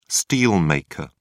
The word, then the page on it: steelmaker: British English